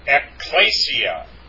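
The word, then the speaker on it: ecclesia (ἐκκλησία): It's not ecclesia. It's ecclesia. Hold on a second. Hey, ecclesia. Yeah, that's how it's pronounced. Hey, notice the accent there. Hey, ecclesia. Ecclesia. You have to put the accent on the iota, the last iota there.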